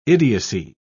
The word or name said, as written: idiocy